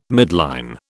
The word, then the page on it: midline: British English